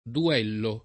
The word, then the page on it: duellare: lingua italiana